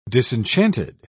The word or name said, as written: disenchanted